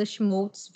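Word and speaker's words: Schmolz: E você também consegue me encontrar nas minhas redes sociais, né, lá no Instagram e no Twitter, como arroba Fernanda Schmoltz. Vem trocar uma ideia comigo. Meu sobrenome se escreve S-C-H-M de Maria O-L-Z.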